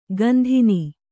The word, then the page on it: ಗಂಧಿನಿ: gandhini